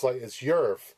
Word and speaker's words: YIRF: It's spelled YIRF. It sounds like Earth, but it's YIRF. Different pronunciation.